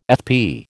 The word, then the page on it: FP: American English